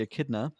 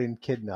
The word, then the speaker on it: echidna: Idris Elba as Knuckles, the Echidna. Colleen O'Shaughnessy. – I never knew they were called Echidna. I always thought they were Echidna before. Because that's how it's spelled. – Yeah, E-C-H-I-D-N-A.